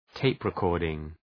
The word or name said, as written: tape recording